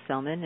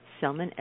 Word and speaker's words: Sellman: I always like to invite people to go to my website, which is Dr. Cheryl Selman, and I'm going to spell that, S-H-E-R-R-I-L-L, Selman, S-E-L-L-M-A-N.